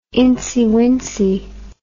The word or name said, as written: incywincy